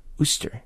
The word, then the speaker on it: Worcester: And randomly, I forget who, someone said to me, I wonder if you mean Ooster. And I'm like, well, yeah, Ustern. No, no, it's Ooster. I'm like, oh, well, yeah, but I can't find it on the map. Oh, yeah. It's like spelled like Worcester.